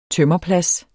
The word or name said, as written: tømmerplads